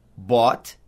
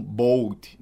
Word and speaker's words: bought: Bought, se pronuncia bought, como se fosse com o mesmo som de cobra. O, bought. Se escreve B-O-U-G-H-T, mas se pronuncia bought, não bold, nada assim. Bought.